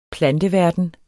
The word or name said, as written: planteverden